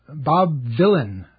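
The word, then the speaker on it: Bob Vylan: The Bob Villain IDF chants in England. It's spelled like Bob Dylan except with a V, and it's clever because Bob Villain is the name of the band, but the singer is Bobby Villain.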